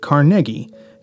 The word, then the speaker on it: Carnegie: The name is actually pronounced Carnegie, not Carnegie or Carnegie, which is how it's usually seen and heard.